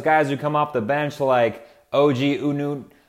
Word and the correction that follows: Anunoby: Oh, I butchered that name. OG Anunoby.